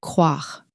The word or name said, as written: croire